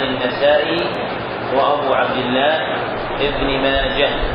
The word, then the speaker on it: ماجه: وماجه بالهاء بالتاء من الهواحي، واحدة ليست بالتاء إنما هي بالهاء، ونطقها بالتاء غلط، فهي وأخواتها بالهاء مندة وماجة. وأشرت إلى ذلك قولي على سبيل الملاطفة لأحدهم: يا صاحي نطق ماجة بالهاء بلا لجاجة، يا صاحي نطق ماجة بالهاء بلا لجاجة.